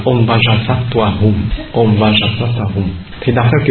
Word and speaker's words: Vajra: Trên Om Vajra thì họ đọc là Om Benza.